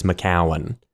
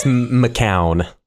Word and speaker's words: McCown: even though there's no vowel between the W and the N in my last name, I grew up as Chase McCowan. The most yokel pronunciation of it. Where I think if you break it down from its more Scottish histories, God, this is so boring. It's McCown.